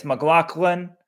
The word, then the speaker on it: McLaughlin: And for the record, guys, it's McLaughlin. We received an email from a New Jersey track coach who said Sydney's dad is a track coach. They always say McLaughlin, and no matter how it's spelled. And John pointed out he found Sydney's signing on New Balance, and she pronounces her last name McLaughlin.